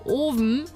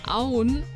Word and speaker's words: Owen: Zum Beispiel, das wird Owen geschrieben, heißt aber Auen. Mhm. Da gibt es sogar einen Merkspruch. Oven sagen nur die Doofen, Auen sagen die Schlauen.